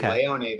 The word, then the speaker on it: Leone: No, it's Italian in origin. It would be Leone there.